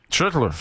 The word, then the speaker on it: Schlittler: Schlittler. J'aime ça de la manière que tu l'as très bien prononcé.